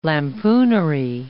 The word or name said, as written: lampoonery